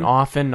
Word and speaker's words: often: Thank you for saying often, not often. – Yeah, the T is silent.